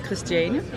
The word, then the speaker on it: Christiane: My name is Christiane. – Can you spell it for me? – It's like Christian with an E.